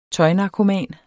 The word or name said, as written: tøjnarkoman